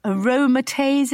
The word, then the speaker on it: aromatase: Also, you are, I presume, on aromatase inhibitors. Is that how you pronounce it? – Yes, I think it is.